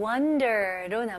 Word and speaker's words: wonder: 다음은 wonder로 넘어갈게요. 네, wonder 발음 연습해 볼게요. 여기서는 O이지만 U 발음으로 생각해 보세요.